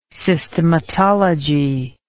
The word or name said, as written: systematology